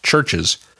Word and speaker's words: CHVRCHES: And if you have a brand name that can't be pronounced, he used the example of the band Churches, who spell their name C-H-V-R-C-H-E-S, even though it's pronounced Churches. And he couldn't search for it via voice activation because you can't pronounce C-H-V-R-C-H-E-S, and his system couldn't recognize C-H-V-R-C-H-E-S.